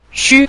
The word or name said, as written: xu5